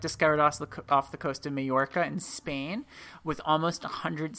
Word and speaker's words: Majorca: Yeah, Majorca. – It's not Mallorca.